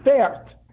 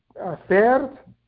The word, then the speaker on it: Pferd: Now, I may be crazy, but the word pered, if you just kind of change the nekudos around a stickle, I think in German it's spelled P-F-E-R-D, Pferd. I don't know if they are so careful to say the P and the F.